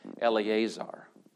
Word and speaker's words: Eleazar: But Eleazar. Eleazar is the Hebrew pronunciation of the New Testament name Lazarus.